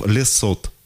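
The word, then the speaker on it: Le Saout: Лесо вообще, вот. Ты обычно не читается. – Не-не-не, вот у него как раз вот «Т» вот у него как раз «Т» читается, ну, значит, на удивление. Читается. Вот.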